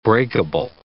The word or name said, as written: breakable